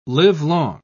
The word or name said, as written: livelong